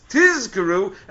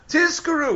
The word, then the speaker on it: tizkaru: so we have to emphasize that it's tizkaru and not Tiskaru.